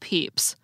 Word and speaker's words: Pepys: But his name is actually pronounced Peeps, like the marshmallow chicks you buy for Easter.